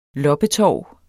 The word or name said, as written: LOPPETORVET